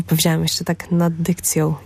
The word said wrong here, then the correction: dykcją: Nie zaakcentowałam tego dobrze. Super w ogóle, to jest mega śmieszne, że tak powiedziałam. Nad dykcją, popracuj.